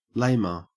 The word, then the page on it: lamer: Australian English